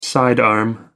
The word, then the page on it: sidearm: American English